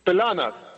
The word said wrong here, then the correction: Planas: No, es Planas, no Pilanas.